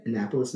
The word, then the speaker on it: Annapolis: Annapolis? Is that how you say it? – Annapolis, Maryland? – Mm-hmm.